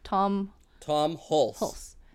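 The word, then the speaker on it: Tom Hulce: Tom Hulse.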